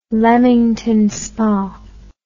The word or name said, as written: Leamington Spa